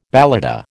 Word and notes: balata: American English

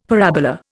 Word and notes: parabola: Indian

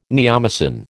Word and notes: neomycin: American English

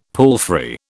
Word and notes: palfrey: British English

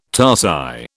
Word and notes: tarsi: British English

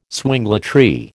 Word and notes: swingletree: American English

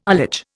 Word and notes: ullage: Indian

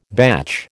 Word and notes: batch: American English

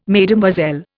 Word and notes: Mesdemoiselles: Indian

